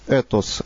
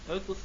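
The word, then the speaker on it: Этос (ἦθος): От греческого этос. Как правильно читается? – Этос. – Да. Этос.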